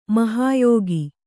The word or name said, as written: ಮಹಾಯೋಗಿ